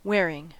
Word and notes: wearing: American English

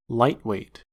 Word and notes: lightweight: American English

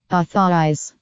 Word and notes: authorise: American English

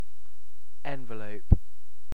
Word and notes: envelope: British English

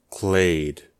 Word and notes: clade: American English

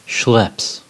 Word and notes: schlepps: American English

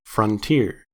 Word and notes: frontier: American English

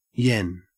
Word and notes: yen: Australian English

